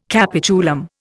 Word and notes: capitulum: Indian

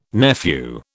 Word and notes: nephew: British English

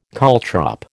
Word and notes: caltrop: American English

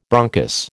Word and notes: bronchus: American English

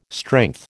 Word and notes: strength: American English

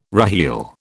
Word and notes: reheel: British English

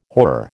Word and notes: horror: American English

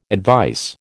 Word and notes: advice: American English

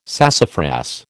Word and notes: sassafras: American English

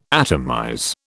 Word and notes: atomize: British English

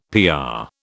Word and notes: PR: British English

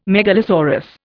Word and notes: megalosaurus: Indian

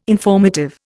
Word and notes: informative: Indian

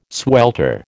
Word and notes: swelter: American English

